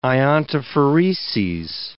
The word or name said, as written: iontophoreses